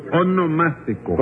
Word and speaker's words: onomástico: Su monoelástico. – No, no, no, su onomástico.